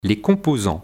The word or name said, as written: Les composants